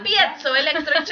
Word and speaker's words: piezoelectric: Creates a piezoelectric charge. – Mm-hmm. Well, that's how I would have gone. That's how I would have pronounced that. A piezoelectric charge.